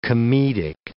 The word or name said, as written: comedic